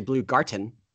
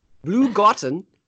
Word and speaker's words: Blue Garten: And since it's so crazy, go spend it at Blue Garden. Actually, Blue Garten. – That's how good. – Spelled. Blue Garten. June 20th. – Spelled like blue cheese and Garten with a T.